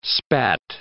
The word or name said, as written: spat